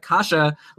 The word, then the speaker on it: Kase: I didn't get any help, except Brian actually wrote to me before we recorded telling me how to pronounce it. And he said it was like Kasha, like Kasha in bow ties, which I thought was a funny way to describe it. But anyway, that's him. His name is spelled K-A-S-E,